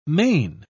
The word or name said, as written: main